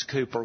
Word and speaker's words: Cowper: That's what we sang, William Cooper's hymn, spelled like Cowper, but it's Cooper, William Cooper.